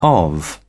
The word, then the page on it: of: British English